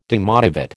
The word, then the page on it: demotivate: American English